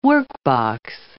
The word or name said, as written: workbox